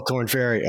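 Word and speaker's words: Korn Ferry: Oh, Corn Ferry. Okay. – And that's how you say it.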